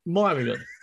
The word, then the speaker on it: Meiringen: And once in Meiringen, Yes. – Pronunciation. – What's that? – Myrian.